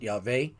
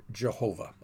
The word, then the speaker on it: Yahweh: Now, I pronounce it Yahweh. Others pronounce it Yahweh. There are other pronunciations. There is a debate and discussion among scholars as to how to actually pronounce it. I happen to pronounce it Yahweh, but definitely you do not pronounce it Jehovah.